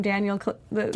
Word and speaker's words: Kaluuya: Daniel Kaluuya.